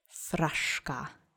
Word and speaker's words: fraszka: Now that you have that fresh on your tongue, accent is on the first syllable, let's say it together. Fraszka.